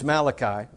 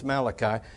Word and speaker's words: Malachi: From Hosea all the way, and I heard somebody say one time, Malachi. It's Malachi, okay? So in case you were confused as to how to pronounce it, it's Malachi.